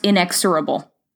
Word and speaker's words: inexorable: How do you pronounce inexorable? – You did it.